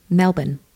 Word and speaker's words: Melbourne: Oh, we've been called out for this, so I was wondering how you pronounce the city, M-E-L-B-O-U-R-N-E. – Mm-hmm. Melbourne.